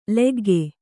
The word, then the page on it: ಲಖ್ಖ: lakkha